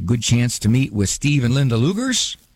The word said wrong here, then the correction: Lugers: Oh, Leaguers, that's the way to pronounce it. Sorry about that. Pronounced Leaguers.